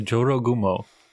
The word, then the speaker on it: Jorogumo: And we are covering the Jorogumo, I believe is how you say it. Can you correct me on that, James? – No, that sounds about right. Okay. The Jorogumo.